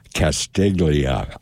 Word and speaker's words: Castiglia: Castiglia. I'm going back to the old pronunciation.